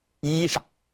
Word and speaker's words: 衣裳: 那么这个词呢，它有两种读法，第一种读法读成衣裳，最后这个字啊读清音。